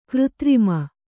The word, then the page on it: ಕೃತ್ರಿಮ: křtrima